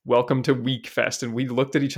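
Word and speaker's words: Wekfest: It's spelled W-E-K and pronounced week. – Okay. I would definitely call that Weckfest, but okay.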